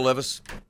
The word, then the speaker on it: Levis: Will Levis and the Tennessee Titans. – It's pronounced Levi's.